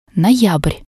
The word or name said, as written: noyabr